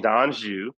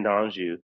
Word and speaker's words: D'Anjou: it's Darion D'Anjou, as as Natasha so well pronounced it, Darion D'Anjou.